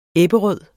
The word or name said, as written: Ebberød